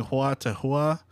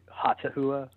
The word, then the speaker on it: Hautahua: I've been saying Huata Hua, H-A-U-T-A-H-U-A. – Yeah, we just say Hata Hua.